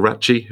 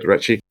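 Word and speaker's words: Raci: The performances across the board, Paul Ratchie, if that's how you pronounce it, Ratchie. – Yeah.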